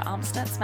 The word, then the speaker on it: Amstutz: And you can find all of my social media at Sandra Amstutz.